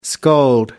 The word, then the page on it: scold: American English